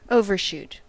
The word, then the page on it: overshoot: American English